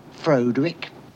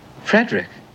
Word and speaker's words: Frederick: Do you also say Froedwick? – No, Frederick. – Well, why isn't it Froedwick Frankenstein? – It isn't, it's Frederick Frankenstein.